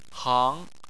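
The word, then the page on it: hang: tone 2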